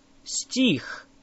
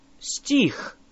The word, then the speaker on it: стих: Стих. Стих. Say их. Их. Тих. Тих. Now add an S at the front of it.